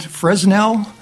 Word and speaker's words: Fresnel: It says, in 1822, French scientist, I'm going to try this French name, Augustin Jean Fresnel, it's spelled Fresnel, but it's Fresnel, I think, was studying optics and light waves.